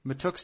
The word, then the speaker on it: Mahtook: M-A-H-T-O-O-K. – I'm told it's Mikey, so Mikey Matuk. – Mikey Matuk, and then Matuk is the last name. Matuk? – I believe so. – We're saying Matuk.